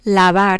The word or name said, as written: lavar